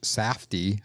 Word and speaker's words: Safdie: It's a movie that was written and directed by the brothers, Benny and Josh Safdie. I think I'm pronouncing that last name correctly. – Correct.